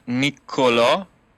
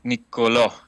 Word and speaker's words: Niccolò: Se dice, se pronuncia bien Nicolò, o sea, con doble C, N-I-C-C-O-L-O, y hay una, un acento en la última O.